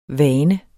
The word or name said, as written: vane